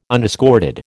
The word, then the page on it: unescorted: American English